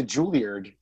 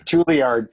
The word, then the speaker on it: Juilliard: And anyway, so especially because Brendan went to Juilliard, he was a student of music. – I have to quibble with your pronunciation of Juilliard. – Juilliard, sorry, Juilliard.